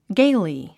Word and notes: gaily: ɡéili; ゲイリィ

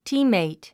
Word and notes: teammate: tíːmmèit; ティーメイト

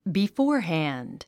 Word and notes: beforehand: bifɔ́ː r hæ̀nd; ビフォアハァンド

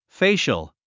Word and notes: facial: féiʃəl; フェイシャル

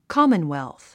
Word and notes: commonwealth: kɑ́mənwèlθ; カモンウェルス

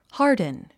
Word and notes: harden: hάː r dn; ハードゥン

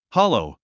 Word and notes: hollow: hάlou; ハロウ

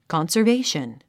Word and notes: conservation: kὰnsərvéiʃən; カンサベィション

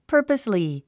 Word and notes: purposely: pə́ːrpəsli; パァパスリィ

